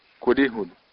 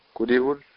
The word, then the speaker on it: curíjul: Escuche bien la pronunciación dicha por una persona que habla el idioma malecu desde la infancia. – Adiós muchacha natoye curihul. Natoye curihul.